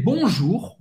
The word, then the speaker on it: bonjour: Non, de manhã ou da tarde, você vai falar simplement bonjour, bonjour bonjour ou U em francês tem o som da lettre U em português e você vai ter que pronunciar esse R no final la palavra que vai arranhar um pouco a garganta.